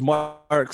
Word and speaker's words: Marc: My first name's Mark, spelled with a C, so...